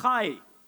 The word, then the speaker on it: chai: And that word life in the Hebrew is pronounced chai, like Papa Chai, almost like father of life if you translate it.